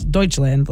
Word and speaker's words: Deutschland: They call themselves Deutschland, like you said.